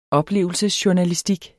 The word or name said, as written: oplevelsesjournalistik